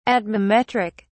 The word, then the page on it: atmometric: British English